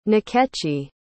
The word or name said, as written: nkechi